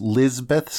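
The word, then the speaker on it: Lizabeth: I have not seen that, but I do know that it's just Lizbeth Scott. – All right, okay. I think the way it's spelled is like Elizabeth without the E.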